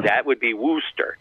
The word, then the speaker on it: Worcester: Worcester. Worcester, is the name of the town where the former Paw Sox are going to be with the new stadium.